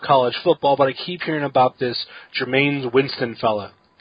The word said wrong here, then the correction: Jameis: Did I say his name right? Because I don't know about college football. – It's Jameis? – That's close. Jameis Winston.